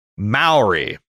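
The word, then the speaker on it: Māori: It says, I know you have a great time with the pronunciation of various names in different shows, but can I point out the correct pronunciation of the word, what I've been saying, Maori, is Maori. Mao is in Chairman Mao.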